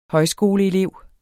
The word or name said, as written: højskoleelev